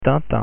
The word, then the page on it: tintin: French